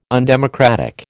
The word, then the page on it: undemocratic: American English